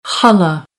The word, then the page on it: challah: American English; ɑː